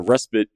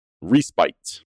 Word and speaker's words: respite: Let me make sure I'm pronouncing it right. It is respite. All right, so I was pronouncing it wrong. Respite is how it's pronounced. So a respite, R-E-S-P-I-T-E, not E-C-T, not the Aretha Franklin song.